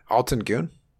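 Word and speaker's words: Altın Gün: Alton Goon? Is that how you pronounce it? – Yeah.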